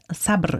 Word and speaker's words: Sabr: Sabr, Geduld. – Genau, auf jeden Fall. Das, richtig ausgesprochen sogar.